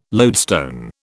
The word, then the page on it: loadstone: British English